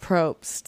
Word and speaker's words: Probst: Jeff Probst, the host. – Yeah. – Jeff Probst, the host.